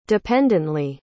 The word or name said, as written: dependently